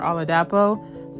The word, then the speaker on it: Oladapo: And then after, my YouTube is always Favor Oladapo.